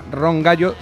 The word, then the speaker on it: Ron Gallo: En Estados Unidos ya empieza a tener mucho nombre, aquí menos, pero va a venir a tocar, creo, que se llama Ron Gallo. Se escribe tal como lo digo, y además eso con... – Ron Gallo. – John, ¿no? Ron Gallo, como el Ron y como el gallo.